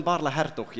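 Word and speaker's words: Baarle-Hertog: Barle Nosso and Barle Hertog, yes.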